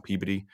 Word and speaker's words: Peabody: That's the town, but it's spelled Peabody. – Okay. – P-E-A-B-O-D-Y, but in Mass, you pronounce it PBD.